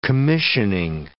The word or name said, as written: commissioning